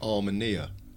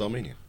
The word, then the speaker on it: Armenia: I'd probably ring up Pete Townsend and say, why did you insist on pronouncing it Armenia, city in the sky? – Yes. – When clearly it's pronounced Armenia.